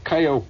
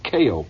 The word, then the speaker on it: Keio: And here is a tape that I recorded while I was sitting 14 stories above Tokyo in a fantastic hotel called Kayo Plaza, or Keio Plaza, it's more properly pronounced. It's spelled K E I O, and it's the tallest building in Japan.